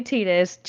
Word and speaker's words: Tidus: There's a whole debate on how you say his name. – Tidus. – Well, people say Tidus, but it's definitely Tidus. Check Kingdom Hearts.